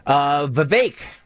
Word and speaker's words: Vivek: Vivek, rhymes with cake.